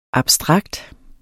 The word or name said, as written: abstrakt